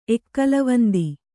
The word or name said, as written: ಎಕ್ಕಲವಂದಿ